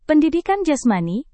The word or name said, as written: pendidikan jasmani